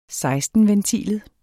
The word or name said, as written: foder